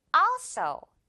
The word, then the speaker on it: also: 자, 그런데 앞에 있는 음절이 오늘 이깁니다. 그래서 also 이렇게 발음해줘요. also 역시 이것도 also가 아니라 also 이렇게. 그리고 역시 앞에 있는 이 어 부분은 길게 also 해주면 돼요.